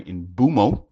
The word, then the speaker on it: Mbeumo: A brand new front three of Matheus Cunha, Brian Mbeumo, is how he's now asked for his name to be pronounced,